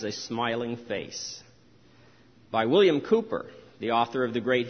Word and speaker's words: Cowper: and his name is spelled as if it were pronounced Cowper, C-O-W-P-E-R, but it's Cooper,